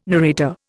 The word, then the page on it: narrator: Indian